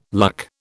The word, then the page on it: luck: British English